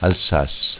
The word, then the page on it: Alsass: dialetto